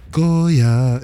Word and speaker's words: Goya: Goya.